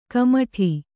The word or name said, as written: ಕುಮುಟು